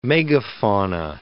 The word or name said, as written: megafauna